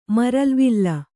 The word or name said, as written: ಮರಲ್ವಿಲ್ಲ